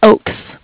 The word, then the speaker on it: Ochs: Okay, that's funny because my last name is OCHS and it's Oaks.